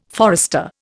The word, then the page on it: forester: Indian